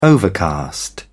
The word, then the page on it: overcast: British English